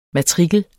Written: MATRIKLERNE

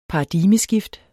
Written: paradigmeskift

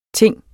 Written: ting

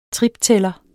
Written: triptæller

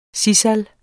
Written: sisal

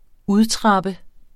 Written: udtrappe

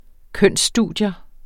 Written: kønsstudier

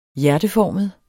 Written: hjerteformet

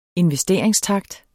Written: investeringstakt

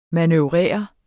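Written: MANØVRERE

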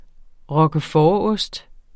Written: roquefortost

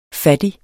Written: fattig

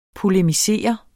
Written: polemisere

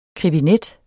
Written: krebinet

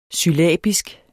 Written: syllabisk

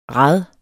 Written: rad

